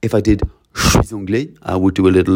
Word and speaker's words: anglais: But I put a dot on the end of the word anglais because I don't say it, yeah.